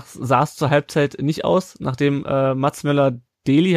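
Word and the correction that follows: Møller Dæhli: habe ich das richtig ausgesprochen? – Mölle Dali. – Mölle genau, Dali, okay, ja.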